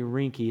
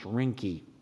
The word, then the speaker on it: Reinke: His name is spelled R-E-I-N-K-E, Tony Rinke. I think it's pronounced Rinke. At the end of that book,